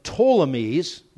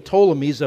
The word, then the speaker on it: Ptolemies: Israel fell under the control of the Ptolemies, with a silent P on the front of it, the Ptolemies of Egypt until 198 BC.